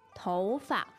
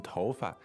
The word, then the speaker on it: 头发: Okay, so 头 is second tone, and 发 is like neutral here? – 对, 但是很多人也把这个发读成第四声。 – Okay, so you might hear it as a neutral tone, as in 头发, or you might hear it as fourth tone.